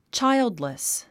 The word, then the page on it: childless: tʃáildlis; チャイルドリス